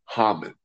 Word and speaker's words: Haman: So the bad guy in the Purim holiday is Haman, or we pronounce him Haman.